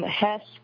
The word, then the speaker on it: Hesse: And also from Hesse, I don't know if I'm saying that right, but you're doing that right.